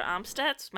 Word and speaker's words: Amstutz: My username is Sandra Amstutz.